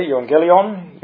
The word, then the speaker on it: euangelion: Now this Greek word, translated gospel, is pronounced, as I've already indicated there, euangelion, euangelizo, and the Greek word for evangelist comes from that same root word.